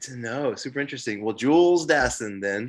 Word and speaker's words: Jules Dassin: Jules Dassin. Exactly. Exactly how Mark Hellinger, which I will talk about fucking Mark Hellinger. I'll talk about him shortly, but exactly how he pronounces it in the opening of the film.